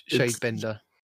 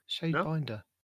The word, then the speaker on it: Shadebinder: How do you say binder in England? Is it Binder, depending on the area that you live in? – No. Huh? Shadebinder.